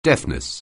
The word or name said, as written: deafness